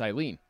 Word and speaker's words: Aileen: We could go A-I-L-E-E-N. – That's Eileen. – Eileen.